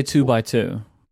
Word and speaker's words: 2x2: How do you say that? 2x2? 2x2? – I say 2x2, because that's what the X typically means.